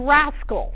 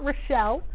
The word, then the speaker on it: raschel: And we're going to learn about the warp knits, which include tricot and raschel. That's how that's pronounced. It is not Rochelle.